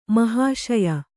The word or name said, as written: ಮಹಾಶಯ